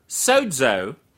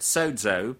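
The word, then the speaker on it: sozo (σώζω): Now, the word saved there is a Greek word, sozo. Now, that's the transliteration of it, sozo, pronunciation.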